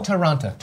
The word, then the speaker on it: Toronto: We are going to a place called, am I saying this correctly? Toronto? – Toronto.